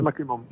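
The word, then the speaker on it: MacCrimmon: Es que el nombre, no sé si lo digo bien, es Macrimon, creo que es. – Sí, Macrimon.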